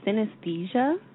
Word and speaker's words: Synesthesia: Yeah, is that Synesthesia? – You got it.